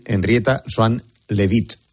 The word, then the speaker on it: Henrietta Swan Leavitt: Henrietta Swan Leavitt.